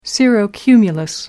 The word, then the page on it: cirrocumulus: American English